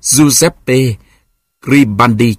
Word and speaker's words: Giuseppe Garibaldi: Pavel nghĩ một lát rồi trả lời, chuyện Giuseppe Garibaldi. Đọc là Giuseppe Garibaldi chứ.